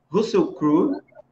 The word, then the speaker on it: Russell Crowe: Conhecido, o extraordinário Russell Crowe não sei se se pronuncia assim corretamente. É, é isso mesmo.